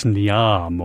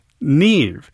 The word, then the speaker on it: Niamh: Next on Classical 89, a piece that is spelled N-I-M-H. You might think it's Niamh or something like that. It's actually pronounced Neve. It's an Irish title.